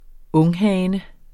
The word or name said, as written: unghane